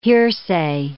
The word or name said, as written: hearsay